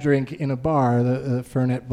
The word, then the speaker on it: Fernet: And he goes, "As I sit there drinking my Ferni Blanca," and it's Fernet is how it's pronounced. I found out later. He just made up a new way to say it.